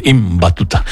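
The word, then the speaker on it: Ibn Battuta: Bueno, pues ahí está, Iván Batuta a través del Islam. – Hijo de Batuta. – Hay que pronunciar bien. Im Batuta.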